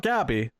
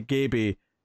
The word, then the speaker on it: Gaby: Not Gabby. They specifically say Gaby that rhymes with maybe.